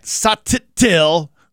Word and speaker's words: Sottile: It's spelled S-O-T-T-I-L-E. And he said, it's Matt Satil. He does the two T's.